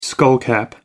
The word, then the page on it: skullcap: American English